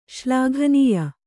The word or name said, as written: ಶ್ಲಾಘನೀಯ